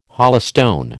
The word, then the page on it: holystone: American English